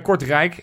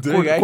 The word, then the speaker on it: Kortrijk: Kortrijk. Kortrijk. Kortrijk. Ja, iemand anders was Langrijk, maar hij was Kortrijk. Kortrijk, ja. – Hij is niet Langrijk geweest. Maar goed. – De, klemtoon lag op de verkeerde...